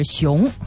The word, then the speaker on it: Sean: 熊就是太囧了这是应该发成是 Sean Sean OK、 yeah. – 被他这个写成了汉字熊